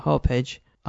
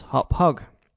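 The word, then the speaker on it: Hauppauge: The FAQ explains, Hopage is a town in New York on Long Island. Hopage is pronounced Hop Hog. So there you go, Hop Hog. I've been pronouncing it as Hopage, as that's the way it looks to me, but it's Hop Hog.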